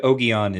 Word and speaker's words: Ogion: Ogion is Le Guin's preferred pronunciation. – Okay. We'll go with Ogion then.